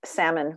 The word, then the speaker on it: Sammon: I'm at Instagram at Grace Sammon Writes. My name is spelled different, spelled like the fish, but spelled S-A-M-M-O-N.